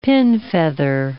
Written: pinfeather

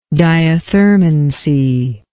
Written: diathermancy

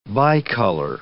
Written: bicolor

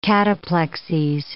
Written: cataplexies

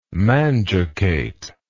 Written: manducate

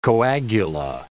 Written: coagula

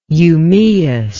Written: eumaeus

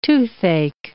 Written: toothache

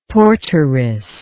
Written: porteress

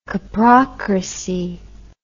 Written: coprocracy

